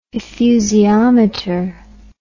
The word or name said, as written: effusiometer